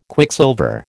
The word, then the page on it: Quicksilver: American English